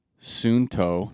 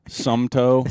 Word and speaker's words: Suunto: And I was pronouncing it Suunto. – Suunto.